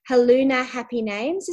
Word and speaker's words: Haluna Happy Names: And the business is, now Helen, you might need to help me to make sure that I'm saying this correctly, Haluna Happy Names, is that right? – That's absolutely right, yeah.